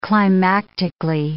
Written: climactically

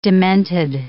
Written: demented

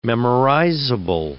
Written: memorizable

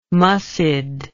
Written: muscid

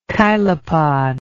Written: chilopod

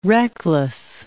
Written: reckless

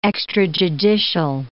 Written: extrajudicial

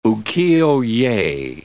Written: ukiyo-ye

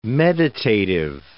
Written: meditative

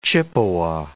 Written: Chippewa

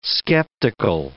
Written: skeptical

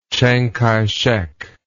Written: Chiang Kai-shek

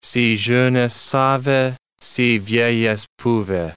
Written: si jeunesse savait, si vieillesse pouvait!

